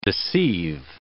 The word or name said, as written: deceive